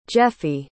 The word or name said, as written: jeffie